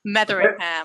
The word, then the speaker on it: Metheringham: Metheringham.